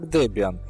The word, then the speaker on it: Debian: Поскольку многие люди об этом спрашивают, слово Debian произносится именно как Debian, хотя среди русскоязычного населения можно услышать и Debian.